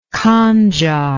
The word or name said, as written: khanjar